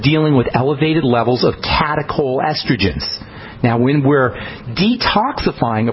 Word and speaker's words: catechol: The breakdown products of estrogen are called catechol estrogens. That's spelled C-A-T-E-C-H-O-L, catechol estrogens.